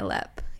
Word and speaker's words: Leap: It's Lep, right? Not Leap. – Yeah, Lep. Yeah. – Even though it's spelled that way.